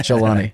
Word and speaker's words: Jalani: Al-Jabari? – Abu Muhammad al-Jalani. – Not Jabari. – Yeah. Jalani.